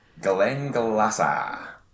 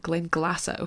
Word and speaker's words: Glenglassaugh: So hi, Global Brand Ambassador for Brown Forman, who own the Benriach, Glendronach, and how do you pronounce the third one, distilleries? – Glenglassaugh. – Ah, Glenglassaugh, okay, great.